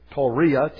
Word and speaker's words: Tawriya: And then the article went on and it said, there is something else, and I'll pronounce it Tawriya, T-A-W-R-I-Y-A.